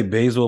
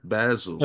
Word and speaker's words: Basel: I can say Basil if I want. Who says it has to be Basil? – That's the pronunciation of it. Art Basel.